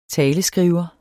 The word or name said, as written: taleskriver